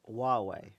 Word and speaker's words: Huawei: Huawei. I'm told that's pronounced. – Yes, that's with an H.